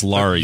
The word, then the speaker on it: Laure: L-A-U-R-E. – Okay, and it's pronounced Laurie. – Yeah. – But on him, it's pronounced Laurie, but is it... – it's Laurie.